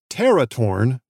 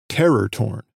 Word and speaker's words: teratorn: That's pteratorn, not terror torn. It's spelled T-E-R-A-T-O-R-N.